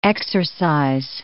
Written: exorcize

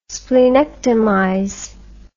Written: splenectomize

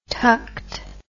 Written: tucked

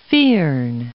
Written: firn

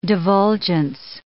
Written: divulgence

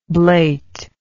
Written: blateness

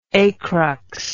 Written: acrux

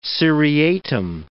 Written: seriatim

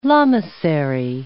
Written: lamasery

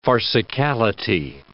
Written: farcicality

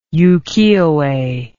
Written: ukiyoe

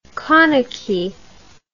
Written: Koneke

